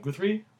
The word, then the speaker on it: Guthrie: Because the whole thing is basically they worked with this guy named Jim Guthrie. – Right. – It's really weird to pronounce his name. Guthrie. G-U-T-H-R-I-E.